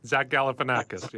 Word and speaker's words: Galifianakis: Um, Zach Galifianakis, yes.